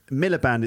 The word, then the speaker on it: Miliband: No, Miller Band, it's spelled M-I-L. How do you spell it? Miller Band. M-I-L-I-B-A-N-D. That's their surname, Miller Band.